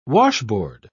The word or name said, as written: washboard